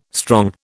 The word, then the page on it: strong: British English